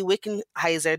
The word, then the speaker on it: Wickenheiser: Also, honorable mention to the Indian women's hockey team who collaborated with Haley Wickenheiser. Did I say that right, Shireen? – You nailed it.